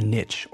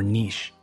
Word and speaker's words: niche: The first expression is to carve out a niche or niche. Both pronunciations are acceptable.